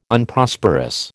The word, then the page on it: Unprosperous: American English